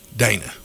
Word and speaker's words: Danna: D-A-N-N-A, how would you pronounce that? Dana or Dana? – Dana.